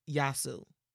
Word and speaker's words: Eyaassu: His name is Yasu, and Yasu is spelled E-Y-A-A-S-S-U, but the best way to pronounce his name is drop the E. So Yasu.